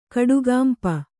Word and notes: ಕಡುಗಂಪು: kaḍugampu

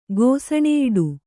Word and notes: ಗೋಸನೆಯಿಡು: kannada

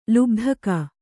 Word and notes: ಲುಬ್ಧಕ: lubdhaka

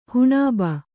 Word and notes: ಹುಣುಬು: huṇube

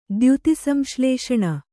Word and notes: ದ್ಯುತಿಸಂಶ್ಲೇಷಣೆ: dyuti samślēṣaṇe

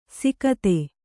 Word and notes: ಸಿಕತೆ: sikate

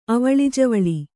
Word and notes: ಅವಳಿಜವಳಿ: Kannada

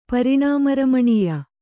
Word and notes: ಪರಿಣಾಮರಮಣೀಯ: pariṇāma ramaṇīya; Kannada

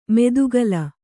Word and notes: ಮೆದುಗಲ: medugala

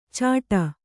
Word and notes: ಚಾಟ: cāṭa

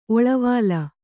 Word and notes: ಒಳವಾಲ: oḷavāla

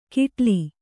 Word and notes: ಕಿಟಲಿ: kiṭali

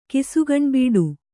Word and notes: ಕಿಸುಗಣ್ಬಿಡು: Kannada

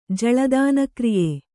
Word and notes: ಜಲದಾನಕ್ರಿಯೆ: jala dāna kriye

